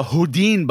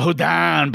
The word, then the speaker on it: Houdin: But this box is actually the Houdin box, or the Houdin box, depending on who's pronouncing it.